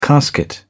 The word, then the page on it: casket: British English